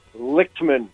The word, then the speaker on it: Lichtman: Lichtman.